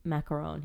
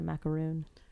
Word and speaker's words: macaron: I love Jay Swanson, although I'm upset that he cannot pronounce macaron. He calls it a macaroon.